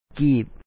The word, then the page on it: ຫ້າກີບບໍ: hâa kîip bɔɔ